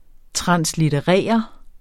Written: TRANSLITTERERE

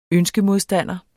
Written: ønskemodstander

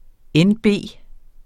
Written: NB

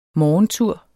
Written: morgentur

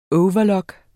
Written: overlock

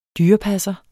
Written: dyrepasser

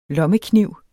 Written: lommekniv

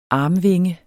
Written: armvinge

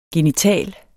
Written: genital

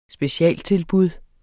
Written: specialtilbud

